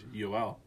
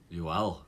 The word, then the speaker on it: Joel: I heard this, I think it was on TSN 1290, that Joel Armia actually prefers Yoel. Yoel Armia. So, a couple new names, a couple new guys joining with Zach Parise. – Yoel, it's almost like Spanish version, like Jose and Juan Cuantro.